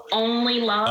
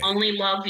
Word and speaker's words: Onleilove: It's Onleilove. – Onleilove, okay. – Onleilove, you, O-N-L-Y-L-O-V-E.